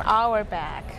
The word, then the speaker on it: Auerbach: Auerbach. – Okay, that's A-U-E-R-B-A-C-H. I didn't know how to pronounce that.